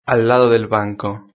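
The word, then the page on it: al lado del banco: Spain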